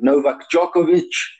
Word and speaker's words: Novak Djokovic: You've worked with many players, Janko Tipsarevic, Novak Djokovic, Boris Pazanski, and Nenad Zimonic, just to name a few.